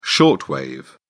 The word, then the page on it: short wave: British English